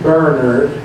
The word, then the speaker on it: Bernard: St. Bernard of Clairvaux, as I say, Bernard, because that's the English pronunciation.